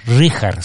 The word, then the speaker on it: Richard: Este es Richard, se pronuncia.